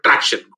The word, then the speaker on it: Tracxn: I think I made a bit of a blunder on the pronunciation of Tracxn. Well, I was on the X Tracxn, but Tracxn. Got it.